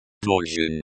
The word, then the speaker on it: должен: The ZH, in, должен, is pronounced similar to the J, in the English word, judge.